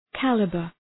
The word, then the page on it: calibre: ‘kælıbər